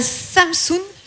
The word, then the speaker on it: Samsun: Tu es née à Samsun, si je le dis bien.